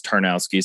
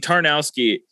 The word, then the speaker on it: Tarnowski: Tarnowski. It's literally spelt with three separate words. Like all you got to do is just like read it all together. It's not that hard.